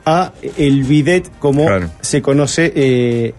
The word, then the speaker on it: bidet: ¿Por qué se le llama bidet al bidet? Porque en Francia... – Que además termina en T, entonces no se debía de pronunciar. – Exacto. En Francia hay un caballo chiquito, tipo pony, que se llama bidet, que casualmente ese caballo era el usado por las doncellas para salir a pasear por los jardines.